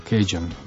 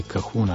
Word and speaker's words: Cajun: Tam pravijo Cajun. Pri nas nekateri bi lahko rekli tudi Cajun ali Cajun, nekateri izgovarjajo to.